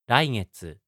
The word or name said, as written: raigetsu